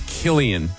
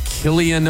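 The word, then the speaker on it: Cillian: I always say his name wrong, but I'm pretty sure it's Killian.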